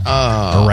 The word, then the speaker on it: Iran: The I can be ire, as in irresponsible. Oh, ear. But R-A-N is ran, not Ron, not Ren, not Rin. Please say ran when you mean ran. Alan, Iran.